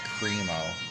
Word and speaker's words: Crimo: His last name is spelled C-R-I-M-O. They've been pronouncing it Cremo.